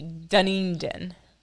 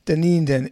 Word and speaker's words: Dunedin: So is the Dunedin, I'm saying it wrong. What's it again? How do you say the name? – So emphasis on the E, Dunedin. – Dunedin.